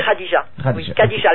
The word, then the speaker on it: Khadija: Khadija. Le K-H, ça se prononce.